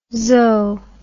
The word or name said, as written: zo